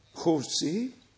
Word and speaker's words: José: Dann haben wir heute drei Brüder in unserer Mitte aus Südamerika, unser Bruder José, das J wird im Spanischen als CH ausgesprochen, unser Bruder José aus Rio ist das wohl, ja, aus Rio de Janeiro.